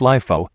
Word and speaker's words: LIFO: Pronounced LIFO, the company assumes that the last item making its way into inventory, or most recent, is assumed to be sold first.